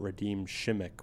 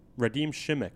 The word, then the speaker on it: Radim Šimek: What are the odds this is the first and last time we hear about Radim Šimek? What's his name? – You said it right. – Radim Šimek.